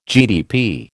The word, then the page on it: GDP: American English